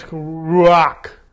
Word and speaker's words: ruach: But the word Hebrew is ruach. R-U-A-C-H is a transliteration of that. And it's ruach.